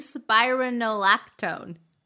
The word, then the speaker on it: spironolactone: And spironolactone. – You put the emphasis on the wrong syllable.